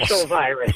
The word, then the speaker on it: syncytial: I think I said syncytial. – Syncytial virus. – Yes.